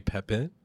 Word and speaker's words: Pepin: Pepin.